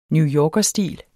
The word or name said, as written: newyorkerstil